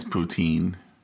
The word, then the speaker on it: poutine: It is poutine. – Poutine. – I had to check multiple subscription pronunciation YouTubes.